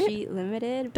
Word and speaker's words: Darjeeling Limited: And then the song before that was Power Man by The Kings from the movie soundtrack Darjeeling Limited. Did I say it right? – Darjeeling Limited by yes, thank you.